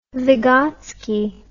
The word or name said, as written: Vygotsky